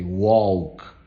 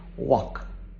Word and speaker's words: walk: Many times, many learners of English as a second or foreign language, they pronounce the L, they say walk, talk, but no, that L is silent. So you have to listen to people saying walk, talk.